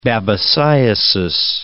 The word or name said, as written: babesiosis